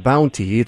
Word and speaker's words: Bounte: which I believe is pronounced Bounty. It's spelled Bounte. Welcome to the Business Lunch. – Well, thanks for having me. I really appreciate it. And yes, you got the name right. It's Bounty, B-O-U-N-T.